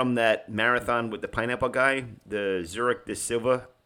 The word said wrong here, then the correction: Sevilla: marathon. Am I saying that right? The Zurich part I got. – Zurich Sevilla. – Sevilla, thank you, marathon.